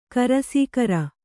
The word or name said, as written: ಕರಸೀಕರ